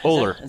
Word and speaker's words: Oler: Oler. – That's how you pronounce it? – Looks like over, but with an L instead of an yeah. – E. Oler.